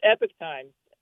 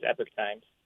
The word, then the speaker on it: Epoch Times: It's Epic Times, but Epoch isn't necessarily wrong for saying that word, but as far as our branding goes, we go with the American pronunciation, which is Epoch Times.